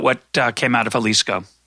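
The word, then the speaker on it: Xalisco: And um, it's spelled with an X, X A L I S C O, the Jalisco.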